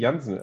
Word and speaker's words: Janssen: It would be Janssen. I mean, she's Dutch, so that's what I would say, of course. But we are German, so we would never think of pronouncing it in an English way.